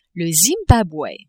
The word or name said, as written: Zimbabwe